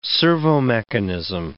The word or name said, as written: servomechanism